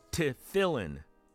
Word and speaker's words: tefillin: Dan writes, it's pronounced tefillin or tefillin.